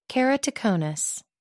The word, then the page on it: keratoconus: American English